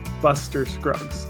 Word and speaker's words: Buster Scruggs: The look of the costume designs in the trailer, I mean in the movie, when I saw The Ballad of Buster Scruggs on Netflix. I think that Netflix is going to break out with a Oscar win with The Ballad of Buster Scruggs. Buster Scruggs.